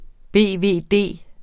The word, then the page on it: BVD: ˈbeˀˈveˀˈdeˀ; beveˈdeˀ